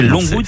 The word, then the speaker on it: Longwood: Voilà, c'était pas Longwood, c'est Longwood. – C'est Sherwood. – Pas du tout Sherwood. Longwood. Longwood. On prononce comme ça, Longwood.